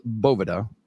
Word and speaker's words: Boveda: The correct pronunciation is Boveda. So if you hear a Spanish speaker pronounce it, it's going to largely be Boveda. – Yeah. Boveda. – Almost like the V is another B.